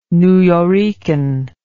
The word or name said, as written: Nuyorican